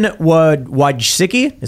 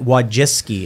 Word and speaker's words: Wojcicki: People call her Wojcicki, but I'm like, how is it Wojcicki? It's Wojcicki.